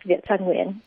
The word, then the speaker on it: Viet Thanh Nguyen: This is Viet Thanh Nguyen. Am I pronouncing that correctly? – Yes, Viet Thanh Nguyen.